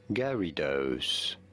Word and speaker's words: Gyarados: So, the official pronunciation Pokemon. Is as Gyarados. Somewhat like Gary and Dose.